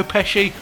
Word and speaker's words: Pesci: Is it Joe Pesci? – Yes. – Joe Pesci? – I've only ever heard Pesci. – Pesci, excellent. Cool, I got one right for once.